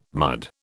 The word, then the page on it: mud: British English